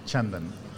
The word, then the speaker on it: Chandan: Chandan, yes.